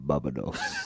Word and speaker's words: Barbados: Nah, I thought that as well, but now I'm looking and I'm thinking, maybe is it Barbados? Is that right? Am I saying that right? – Barbados. – Oh, thank you.